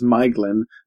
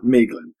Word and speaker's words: Maeglin: So Maedhros and the nephew of Turgon, who's going to get his own chapter in a couple of weeks, is Maeglin, not Maeglin.